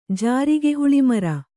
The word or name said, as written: ಜಾರಿಗೆಹುಳಿಮರ